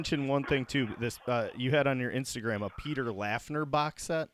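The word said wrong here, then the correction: Laughner: Yeah, it's actually, which I just learned, his name is pronounced Peter Lochner.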